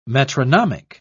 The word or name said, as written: metronomic